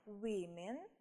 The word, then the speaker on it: women: A child – children, a man – men, a woman – внимание, women. Угу. Помним, да, что произношение тоже меняется.